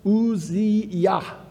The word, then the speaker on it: Uzziah: It says this, it says, in the year that King Uzziah, is how you really should pronounce it, and that Yah at the end, it comes from Yahweh, the one who gains his strength from Yahweh.